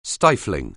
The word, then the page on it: stifling: anglais